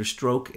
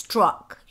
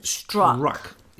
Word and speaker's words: Strzok: There was a counterintelligence investigation of him by, Peter Strzok and his boss, whose name is... – It's Strzok. You pronounce it Strzok.